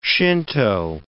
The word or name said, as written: Shinto